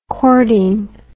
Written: chording

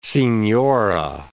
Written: signora

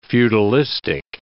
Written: feudalistic

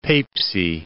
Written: Peipsi

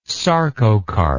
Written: sarcocarp

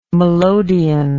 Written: melodion

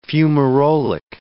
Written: fumarolic

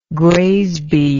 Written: graysby

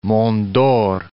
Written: Dore, Monts

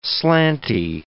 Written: slanty

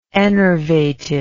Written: enervated

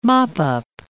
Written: mop-up

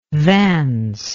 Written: vance

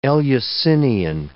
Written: Eleusinian

